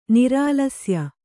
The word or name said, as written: ನಿರಾಲಸ್ಯ